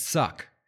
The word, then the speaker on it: Suq: conquered Socotru and built a fortress at Suk. S-U-Q. That's how it's pronounced.